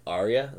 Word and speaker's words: Arya: I think in the end it's going to end up with somebody like Jon Snow or Arya.